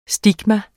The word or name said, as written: STIGMATA